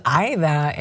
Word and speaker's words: Aida: The four letter name, my name is Aida, and I mean, I get Aida, but I've been called Audia.